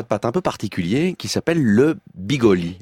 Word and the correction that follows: bigoli: Les bigoli. I-bigoli. – I-bigoli. I-bigoli. Absolument, j'ai mis l'accent tonique au mauvais endroit.